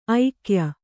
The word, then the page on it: ಐಕ್ಯ: aikya